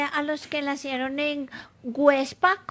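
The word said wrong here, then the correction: Güepsa: Perdón, Huepsa. Websa. ¿Ah? Con diéresis.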